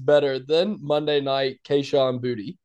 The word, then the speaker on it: Boutte: Like, I think Tessitore and McElroy were just wrong the whole night, because if a player plays for LSU and it's his name is spelled that way and it's been called Boutte the whole time, I'm just like, how did it all of a sudden become Booty? Like,